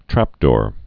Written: trapdoor spider

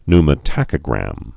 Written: pneumotachogram

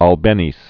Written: Isaac Albeniz

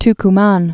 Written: Tucuman

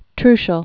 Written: Trucial Oman

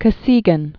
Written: Aleksei Nikolayevich Kosygin

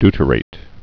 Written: deuterated

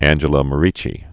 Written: Saint Angela Merici

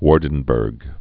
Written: Waardenburg syndrome

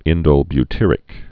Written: indolebutyric acid

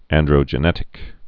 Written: androgenetic alopecia

